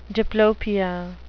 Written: diplopia

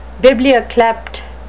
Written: biblioklept

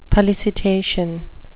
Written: pollicitation

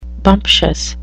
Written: bumptious

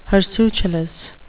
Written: hirsutulous

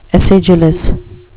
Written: acidulous

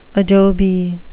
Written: adobe

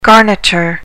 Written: garniture

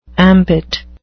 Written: ambit

